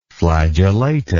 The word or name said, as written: flagellata